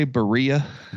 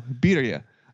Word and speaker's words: birria: It wants me to say Berea. Nope. Berea. – I know, birria. I know that's that's how it's pronounced, but there's I even order it that I have I have ordered it that way.